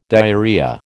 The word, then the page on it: diarrhoea: American English